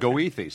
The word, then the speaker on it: Goethe: Hey, in Chicago, it's pronounced Goethe, so I feel a lot better.